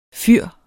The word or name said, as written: fyr